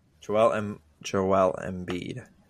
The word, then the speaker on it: Embiid: Joel Embiid.